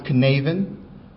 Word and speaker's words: Cynefin: In the middle, there's a red line that says modified, and that is spelled C-Y-N-E-F-I-N, but it's pronounced Canavan, the Canavan model.